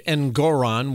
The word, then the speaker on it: Engoron: By now, you've heard the stunning verdict Friday that this Judge Engoron, which rhymes with moron, levied against President Trump and his company and his two sons.